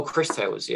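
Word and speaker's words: Cris Tales: Crease Tales. How do you pronounce that? Chris Tales? – Oh, Chris Tales, yeah.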